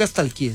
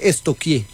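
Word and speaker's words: stalkeé: Porque no se dice nunca estalquié, se dice estoquié.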